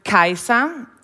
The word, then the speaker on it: Kajsa: So it's Kajsa. In Sweden, we don't pronounce the J, it's like a Y.